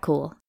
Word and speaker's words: Kuhl: I'm Kat Kuhl,